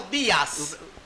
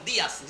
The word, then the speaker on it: Díaz: Pero el apellido, ¿cómo se escribe el apellido? – D-I-A-Z. – Ah, Ulpiano Díaz. D-I-A-Z. Ulpiano Jazz, no, Díaz. – Díaz.